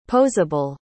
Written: posable

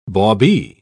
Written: bawbee

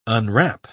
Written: unwrap

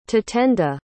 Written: tatenda

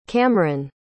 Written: Kamryn

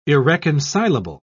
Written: irreconcilable

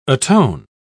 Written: atone

